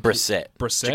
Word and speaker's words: Brissett: Yeah, I think is it Brissett? That's how you say it. I think Brissett. – Jacoby Brissett.